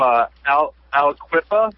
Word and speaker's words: Aliquippa: I mean, seems like he was from Aliquippa. Is that how you say it? – That is.